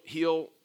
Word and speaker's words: heal: Heal. H-E-A-L, are you with me? Okay, not like a hill that you go over, but heal.